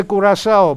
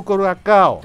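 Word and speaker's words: Curaçao: Ναι, αλλά εμείς δεν το λέγαμε κουρασάο, μπλου κουρασάο. Το λέγαμε μπλου κουρακάο. – Το λέγαμε μπλου κουρακάο γιατί γράφεται με σε το κάο.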